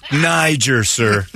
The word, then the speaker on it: Niger: It's pronounced Niger, sir. – Not where I come from. It's not right. What? I've been saying it wrong all these years.